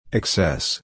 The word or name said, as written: excess